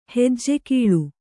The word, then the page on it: ಹೆಜ್ಜೆಕೀಳು: Kannada